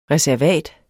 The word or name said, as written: reservat